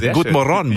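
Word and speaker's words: God morgon: Good Morgen, muss ich sagen. Okay, Betonung auf der letzten Silbe.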